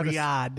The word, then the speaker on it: Riyadh: Riyadh.